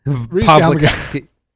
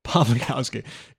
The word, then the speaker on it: Pawlikowski: It's a V. Pawlikowski. – Pawlikowski. God, it is.